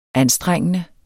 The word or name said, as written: anstrengende